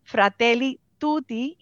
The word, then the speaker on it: Fratelli Tutti: Se trata de una encíclica papal que lleva por nombre Fratelli Tutti y nosotros la interpretamos como la, encíclica sobre la fraternidad y la amistad social. Lo dije bien, doctor. – Lo, dijo perfectamente, lo dijo perfectamente.